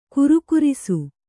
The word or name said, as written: ಘರ್ಘರಿಸು